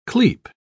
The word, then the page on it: clepe: British English